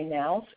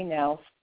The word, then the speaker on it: Knaus: So I've just read for you an excerpt from the book, Do It Now, Break the Procrastination Habit, by Dr. William J. Nous, and his last name is spelled differently, K-N-A-U-S. Again, Dr. William J. Nous, spelled K-N-A-U-S.